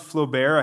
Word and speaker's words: Flaubert: His name was Gustave Flaubert. I had to write it down as Flo-Bear, so I'd say it right.